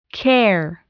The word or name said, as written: care